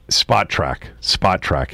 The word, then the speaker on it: Spotrac: Spotrac is how you pronounce it.